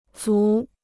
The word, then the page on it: 醋: cù